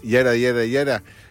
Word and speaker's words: yada: ¿Se acuerdan que hay un episodio de Seinfeld que se llama Yada, se escribe Yada, pero no, es Yara,